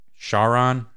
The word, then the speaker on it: Charon: I don't know how you say his name. Is it Sharon, the guy that the boat? – Yeah. – Yeah. Chiron. Chiron. Yeah. Sharon.